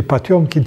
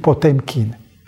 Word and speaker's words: Potemkin: Acum citesc cum se citește, nu în rusește, Potiomkin, ci Potemkin. Are o altă pronunție, dacă citim pe litere, nu rusește.